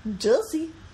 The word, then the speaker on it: Jussie: Are you just supposed to pronounce his name like Jesse? – I think it's Jussie. – Jussie? – Jussie. – Okay.